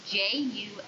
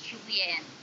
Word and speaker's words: Juliann: It's Juliann. There are no E's. There are two N's. It is pronounced Julianne.